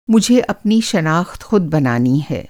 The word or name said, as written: शनाख़्त